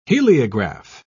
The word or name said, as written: heliograph